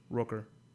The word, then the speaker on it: Rooker: Rooker. – Just so anyone knows, that's actually how you pronounce his name.